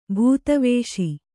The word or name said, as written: ಬೂದಿವೂಸು